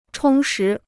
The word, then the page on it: 充实: chōng shí